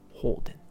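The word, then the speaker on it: 宝殿: 法殿というですね宝にお殿様の殿と書いて法殿って言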